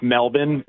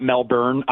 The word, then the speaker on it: Melbourne: By the way, It's pronounced Melbourne, not Melbourne.